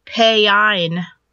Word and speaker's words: Pan: Payine. – Yeah. – P-A-Y-I-N-E. Payine instead of just Pan.